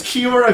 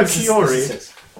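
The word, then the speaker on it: Kia ora: He pronounces Kiora Kiori.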